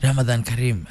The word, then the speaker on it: Ramadan Kareem: Ramadan Kareem.